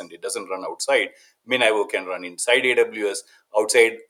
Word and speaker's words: MinIO: Uh, MinIO, uh, some people call it Minio. The official pronunciation is MinIO.